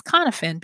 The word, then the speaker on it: Cynefin: And Konfin, if you want to look it up, is spelled C-Y-N-E-F-I-N, but it's pronounced Konfin because it's a Welsh word.